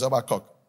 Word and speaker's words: Habakkuk: If you say Habakkuk, you're correct. It's Habakkuk. Okay. Okay. For you, why is for you? Shall I expand? Okay. Habakkuk 2:1.